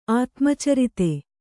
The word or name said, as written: ಆತ್ಮಚರಿತೆ